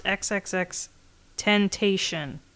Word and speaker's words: XXXTentacion: It's pronounced XXXTentacion. – Really? – Tentacion. That's what it says here. – Oh, like because the X sensation. – No, Tentacion. At the end, it's Sion.